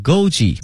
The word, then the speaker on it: Goji: Goji berry 哈 ,Goji, G-O-J-I, 跟我们汉语的发音是差不多的。 Goji berry 哈 ,G-O-J-I,